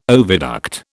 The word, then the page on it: oviduct: British English